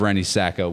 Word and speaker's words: Rennie Sacco: And I have Rennie Sacco with me here tonight.